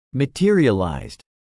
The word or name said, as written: materialized